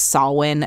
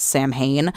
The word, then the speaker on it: Samhain: So it's like it would have taken you 30 seconds to find out that it's pronounced Samhain and not Samhain.